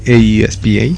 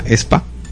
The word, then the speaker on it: aespa: El primero, el grupo no se llama AESPA, de hecho se llama ESPA. – ESPA, sí, o sea, se pronuncia, o sea, así, ESPA. – Se escribe AESPA y se pronuncia ESPA.